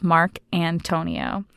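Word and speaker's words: Marcantonio: It's actually really easy if you look at it, the syllables, Mark Antonio.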